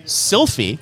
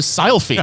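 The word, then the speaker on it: Silfy: Sylphie. – Can you spell that? – S-I-L, now not P-H-Y, it's S-I-L-F-Y.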